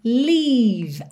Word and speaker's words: leave: Atenção para você fazer a pronúncia desse leave aqui, que é o L-E-A-V-E, diferente do live, morar ou viver, L-I-V-E tá? Então, live é curtinho, é mais vertical. Live, aqui é o leave. Então, hum, hum, saboreia esse som, lembra de deixar ele compridinho, tá?